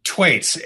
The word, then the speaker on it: Thwaits: Thwaits, H is silent.